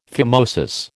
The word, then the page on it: phimosis: American English